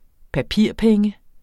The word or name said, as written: papirpenge